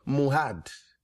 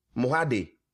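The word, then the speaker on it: Mohad (מועד): We said the feast, the word feast is what? Mohad. Mohade. If anyhow you want to pronounce it. Mohad in the Hebrew.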